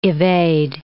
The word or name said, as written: evade